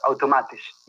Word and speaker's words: Automatisch: Yes, Automatisch. Yes. – Automatisch? Yes. And so is that the name of your art book or? – Yes, so the art book is called Neopop, The Art of Automatisch.